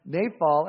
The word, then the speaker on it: naphal: And it's worth noticing because in Hebrew, the word lot is a word pronounced naphal, and it means to throw down.